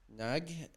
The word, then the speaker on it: Nagy: It's Nag Emma Quintet Nag. I'm really bad at it, but it's spelled Nagy Emma Emma Quintet.